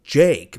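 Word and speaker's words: JVKE: His name is, it's Jake, but it's spelt like Javiki, and if you Google it, it's J-V-K-E.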